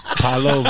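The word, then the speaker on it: Paolo: Is it Paola or Paulo? It's Paulo, Banchero. – Right? – Ventero. – Oh, Brazilian. – Paola Ventero. – Paulo Ventero.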